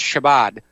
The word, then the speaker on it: Shabbat: I believe it's pronounced Shabbat, and take a look at that.